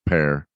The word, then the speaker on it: Per: But Bok Saga says the S equals sun, the creative force, per the All Father, which is actually pronounced, it's P-E-R, but it's pronounced pair.